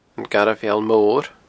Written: Garbh Mheall Mor

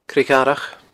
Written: Creag Dharach

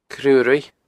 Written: Cruaruigh

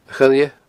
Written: coille

